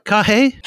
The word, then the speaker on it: Carrhae: Am I pronouncing it right? Carrhae? – Carrhae.